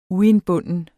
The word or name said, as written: uindbunden